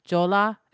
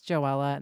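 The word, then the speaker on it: Joela: They go, Jola. And I'm like, it's Joella.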